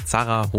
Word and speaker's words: Zara: Der Grund dafür soll so eine Satin White Leg Zara Hose sein.